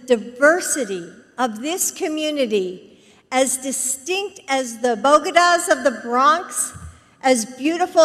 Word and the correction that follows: bodega: but even I find myself flabbergasted by bodegas. Now, she means, of course, bodega, but she pronounces it like